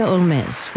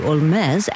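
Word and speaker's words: Holmès: Originally pronounced her last name like Sherlock Holmes, but Frenchified it to Olmes after becoming a citizen of France at the age of 24.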